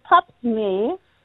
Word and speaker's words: Pap smear: Και το άλλο που για το Pap Smear, έτσι είναι η προφορά του, είναι και στα αγγλικά Pap Smear, το τεστ Παπα-Νικολάου.